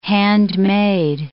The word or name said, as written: handmade